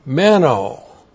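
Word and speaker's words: Meno (μένω): Meno. M-E-N long O.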